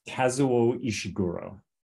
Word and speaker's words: Kazuo Ishiguro: Kazuo Ishiguro.